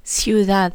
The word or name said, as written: ciudad